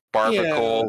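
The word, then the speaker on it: Barbaracle: Barbarical? Is that how you say it? – Little form. Barbarical. Yeah. Barbarical. – Yeah, that thing.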